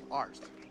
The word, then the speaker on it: Arzt: A-R-Z-T. Arst. Sorry, man. Name's hard to pronounce.